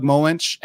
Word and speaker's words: Moench: This is classic Doug Moench and Bill Sienkiewicz. I know I pronounced Sienkiewicz correctly. I think it's Moench.